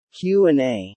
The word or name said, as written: QA